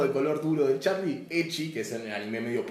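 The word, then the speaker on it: ecchi: Eh, Echi es la pronunciación de la palabra H, la primera palabra del hentai.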